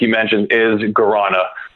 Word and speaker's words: guarana: One of the things I thought was really interesting was that your beverage brand is made with guarana. Guarana? Is that the right way to say yeah. – it? – Yeah.